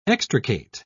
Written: extricate

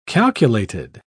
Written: calculated

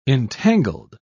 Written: entangled

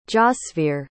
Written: jasveer

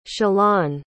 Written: shalan

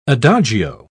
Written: adagio

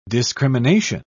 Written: discrimination

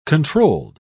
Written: controlled

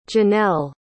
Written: jeannell

